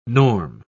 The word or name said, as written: norm